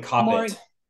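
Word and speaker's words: Kopit: Because you had the role of Phantom in Phantom, I hope I'm pronouncing this right, a Yeston Lottie musical. – Yeston Coppet.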